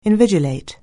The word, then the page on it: invigilate: anglais